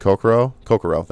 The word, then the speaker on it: Kokoro: Because with Mitsuru and Kokoro, that's how you say her name.